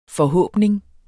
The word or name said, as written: forhåbning